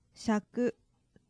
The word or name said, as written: shaku